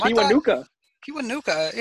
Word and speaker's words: Kiwanuka: I mean, I liked Matthias Kiwanuka, however you pronounce his last name. – Kiwanuka.